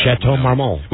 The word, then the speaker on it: Chateau Marmont: Correct, Chateau Marmont, which is close to the Wyndham Bellage, where we stayed one time with a wonderful.